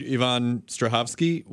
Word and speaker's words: Yvonne Strahovski: And I feel like I interviewed Yvonne Strahovski once.